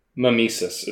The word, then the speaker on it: Mimesis: It's Nemesis with an M. – Mimesis is how I would usually pronounce that.